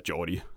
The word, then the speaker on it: Geordie: Newcastle on Tyne, som den i virkeligheden hedder, og Newcastle, som de indfødte faktisk udtaler det eller Jordi, som de udtaler det i det her tilfælde.